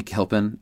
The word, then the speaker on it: Gilpin: I said it wrong earlier. Betty Gilpin.